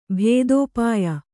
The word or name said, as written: ಭೇದೋಪಾಯ